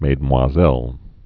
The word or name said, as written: Mesdemoiselles